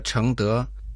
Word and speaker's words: 承德: Давайте разберем название города Чэнгдэ. Чэнг означает «принимать», произносится во втором доне, а «дэ» – «благодетели» тоже читается во втором тоне.